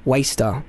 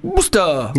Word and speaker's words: Worcester: So the producers very, very kindly said very clearly, this is pronounced Waster. Because I definitely would have gone Worcester.